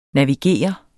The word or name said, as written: Navigerer